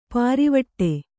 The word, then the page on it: ಪಾರಿವಟ್ಟೆ: Kannada